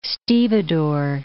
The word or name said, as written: stevedore